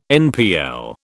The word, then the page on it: NPL: British English